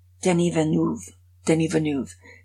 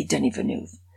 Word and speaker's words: Denis Villeneuve: But I did learn how to pronounce the director's name. Denis Villeneuve. If anybody asks you, just say Denis Villeneuve, and you're going to be great.